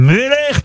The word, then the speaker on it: Müller: Ou Müller. Ça s'écrit M-U-E-2-L-E-R.